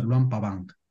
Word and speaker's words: Luang Prabang: Se escribe Luang Prabang, pero se pronuncia Luang Pabang, nada más.